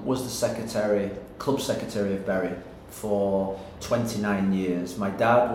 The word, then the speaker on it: Bury: And I'll ask a little more about Man United in a moment, but you just sort of talked about, and I know I mispronounced it, Bury. – Bury, yeah. – Bury. See, I mispronounced it. Some people say Bury. Yeah. Some people say Bury, you know. You pronounced it right.